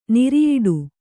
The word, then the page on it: ನಿರಿಯಿಡು: Kannada